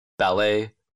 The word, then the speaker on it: ballet: Буква T не читается в некоторых словах. – Ballet, Christmas. – Ш в сочетаниях T плюс T-I-O-N, T-I-A-L, T-I-E-N-T.